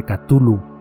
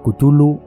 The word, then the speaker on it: Cthulhu: Cthulhu o Cthulhu o Chulhu o Tulu o Putulu como quieras pronunciarlo porque según el propio Lovecraft las gargantas humanas no están diseñadas para pronunciar su nombre así que cualquier pronunciación que quieras darle la que más cómoda te resulte es válida.